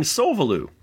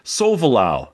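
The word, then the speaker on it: Solvalou: And all this time, I've been mispronouncing the name of your spaceship in Xevious. I keep saying Sovalu.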